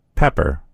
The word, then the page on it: pepper: American English